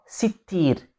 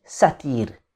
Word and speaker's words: Sittir: Seen ta ya ra Sittir. With a kasra and a shadda on the ta and also a kasra. Sittir. However, I've come across other scholars who have differed with this way of pronouncing the name of Allah. So they agree this is a name of Allah. But instead of saying Sittir, they say Allah is Satir. Allah is Satir. So it's still spelt the exact same way, but the way they pronounce it is Satir as opposed to Sittir.